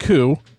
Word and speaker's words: Coup: It's not Coop. – I got it because it rhymes with soup. What's it called? – That doesn't even make grammatical sense because if it was coup. – It's like a coup d'etat.